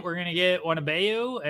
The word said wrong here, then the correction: Wembanyama: and I think it's how you say his name. I should probably learn if he's gonna be Victor Wembanyama. – Wembanyama, that's who. That's how you say it.